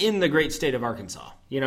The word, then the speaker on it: Arkansas: Akron-SAS is ranked 16th. – Arkansas for the rest of the people.